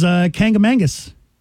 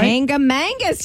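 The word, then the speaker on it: Kancamagus: Second on the list was Kangamangus. Did I say that right? – Kangamangus. Yes, you did.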